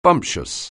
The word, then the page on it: bumptious: anglais